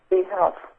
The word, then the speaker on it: Z-Health: V-Health. – V as in Victor? – Z as in zebra. – Oh, Z. H-E-L-P or health? – H-E-A-L-T-H. – Z-Health. Dot com.